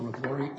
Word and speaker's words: Miglore: May it please the court and counsels, my name is Salvatore Miglore, M-I-G-L-O-R-E, and I apologize for my voice.